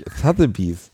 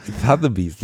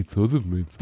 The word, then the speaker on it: Sotheby's: Sotheby's. Jetzt ist es schon besser. Es wird langsam besser. Beim ersten Mal hast du sowas gesagt wie Sotheby's.